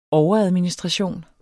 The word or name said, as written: overadministration